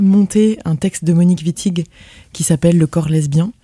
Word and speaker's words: Wittig: Wittig, ouais.